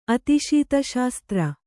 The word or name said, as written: ಅತಿಶೀತಶಾಸ್ತ್ರ